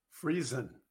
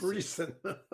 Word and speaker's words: Friesen: Friesen, like Friesen. Friesen.